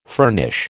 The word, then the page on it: furnish: American English